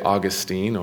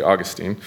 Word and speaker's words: Augustine: You can say it Augustine or Augustine. I'm going to do, because the Latin is Augustinus, I'm going to say Augustine. Now you could have a whole conference on the battle between how to pronounce it, but you know, one's a city in Florida and one's a man, and we'll go on from there.